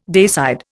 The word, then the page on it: dayside: Indian